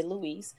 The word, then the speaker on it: Louis: And her last name is P-I-E-R-R-E. And then there's a dash and then L-O-U-I-S, and it's pronounced Louis, or as she said, Louise.